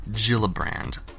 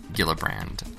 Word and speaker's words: Gillibrand: It's actually Kirsten Gillibrand, not Gillibrand.